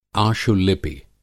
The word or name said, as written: आशुलिपि